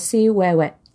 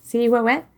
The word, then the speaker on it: Ciuhuehue: So before she was La Ciguanaba, she was known as Ciuhuehue. And I hope I said that right. – That sounds right. – Right? Ciuhuehue.